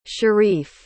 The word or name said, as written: shareef